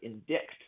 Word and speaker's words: indict: Indict, it sounds like what you spelled sounds like indict. – Right, and that is frequently how I hear it pronounced in class from students if English is not their first language, but it's indict. The C is silent.